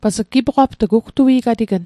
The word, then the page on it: gipoqapt'g: Mi'gmaq